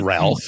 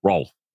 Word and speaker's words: Rolf: No, it's Rolf. Not Ralph. It's Rolf. – Rolf.